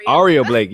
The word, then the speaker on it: Aria Blake: I mean, you've wrestled another Clearwater native and Ariana Blake. Blake. Aria Blake. Yes, I keep saying say it like the hotel, and I just keep forgetting.